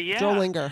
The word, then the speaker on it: Drollinger: Drollinger.